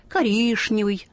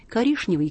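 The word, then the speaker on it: коричневый: И есть старшие, которые будут настаивать, коришневый, достаточно. Коришневый, конечно, уже ушел. – Так же, как булочная. – Да, и булочная, в общем, ушла. Уже говорят так, как пишется, молодые.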